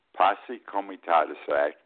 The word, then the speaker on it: Posse Comitatus: the correct pronunciation, Posse Comitatus Act, because back then they didn't want the federal government involved in shooting people.